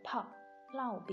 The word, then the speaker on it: 炮烙: 啊，大炮的炮，烙饼的烙，在这里呢要读成“ 炮烙”。